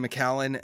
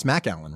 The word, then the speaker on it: Macallan: That bottle was a collaboration between Macallan and at the time, the number one restaurant in the world, the Della Rocca Brothers.